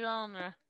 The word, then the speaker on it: genre: Je genre. – Genre. – The soft G.